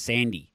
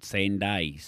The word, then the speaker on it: Sanday: Yeah, it's a town in Scotland, and it is pronounced by the locals there as Sandy. So that's where he goes with that, but the owners apparently want it called Sanday, so...